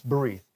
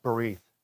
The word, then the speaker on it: berith: If you want it, it's berith. B-R-I-T, but it's pronounced berith.